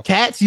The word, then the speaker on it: Katz: You know, Katz, you pronounce the Z.